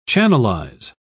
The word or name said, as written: channelize